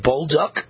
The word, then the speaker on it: Bolduc: I think his name is Bolduc, is how you pronounce it, General Bolduc.